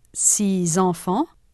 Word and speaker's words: six enfants: In six enfants and dix enfants, six and dix are followed by a vowel. There is liaison. They are pronounced six enfants and dix enfants. 3. In six garçons and dix filles, the word six and dix are followed by a consonant. The letter X does not represent a sound.